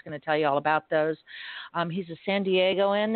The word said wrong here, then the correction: San Diegans: It's San Diegans, by the way.